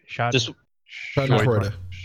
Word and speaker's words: Schadenfreude: I can't pronounce the word. Is that German word? Schadenfreude. – Schadenfreude.